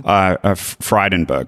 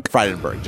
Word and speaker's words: Frydenberg: Frydenberg. – Frydenberg.